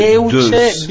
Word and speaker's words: Deuce: Si chiama Douso Bigelow. Si va sul sito 35. – Si dice Deuce Bigelow. – Scusami, c'è anche la ragazza che conosce le lingue, non sai come si pronuncia Deuce.